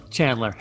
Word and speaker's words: Chandler: Is it Chandler with a hard C or a soft like an S Chandler? – Somewhere in the middle, Chandler.